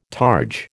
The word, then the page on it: targe: American English